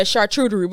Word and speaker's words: charcuterie: And a charcuterie board. – Is that how you say it? – How do you say it? – Charcuterie.